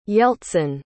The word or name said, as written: yeltsin